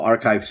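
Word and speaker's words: arXiv: So Archive Sanity, it's spelled RXIV,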